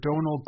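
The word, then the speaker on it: Domhnall: The right way to say it is Donal.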